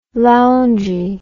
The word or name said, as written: loungy